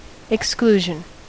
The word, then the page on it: exclusion: American English